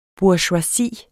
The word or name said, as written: BOURGEOISIER